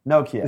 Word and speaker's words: Nokia: He says Nokia. Nokia.